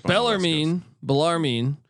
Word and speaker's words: Bellarmine: Bellarmine. How do we say this? – Bellarmine.